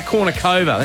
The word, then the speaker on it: Kournikova: I wonder if he's still with Anna or Anna Kournikova. Now, look, I'm going to hurt myself. I wonder if he's still with Anna Kournikova.